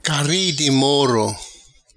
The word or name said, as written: Cari di moro